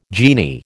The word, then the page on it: genie: American English